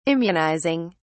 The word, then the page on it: immunizing: British English